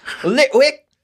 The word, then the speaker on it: Litwick: Litwick! That's how they say it here, yeah.